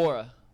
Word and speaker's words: Nwora: Okay, that makes more sense to Indiana gets Jordan Nwora. Is that how you say his name? From Milwaukee. – Nwora.